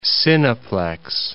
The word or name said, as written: cineplex